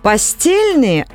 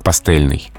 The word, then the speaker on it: пастельный: Слово давно в русском языке, но твердое произношение сохраняется и в существительном «пастель», и в прилагательном «пастельный». Ну, видимо, для того, чтобы отличать от постели и постельного.